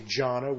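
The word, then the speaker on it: jhāna: The word he would use, he'd say, go do jhana. They pronounce it differently in Pali. They say jhana, which is J-H-A-N-A.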